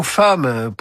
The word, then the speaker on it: femme: Il y a aussi le mot femme. Pourquoi le prononce-t-on avec le son A et non le son E? Comme flemme, par exemple.